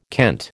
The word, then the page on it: kent: American English